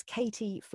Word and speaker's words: Katie: So my name is Katie Flamman,